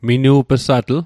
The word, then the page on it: minu'p'satl: Mi'gmaq